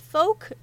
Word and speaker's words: Fouke: There's a few different ways to say it that are acceptable, but we're going to be sticking with the most common one, Fouke like poke.